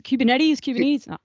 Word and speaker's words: Kubernetes: I didn't actually know what Kubernetes? – Kubernetes.